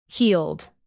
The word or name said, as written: heeled